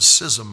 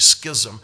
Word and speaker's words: schism: We don't use that word schism much. In fact, sometimes we don't know how to pronounce it. We say schism, and it's supposed to be schism.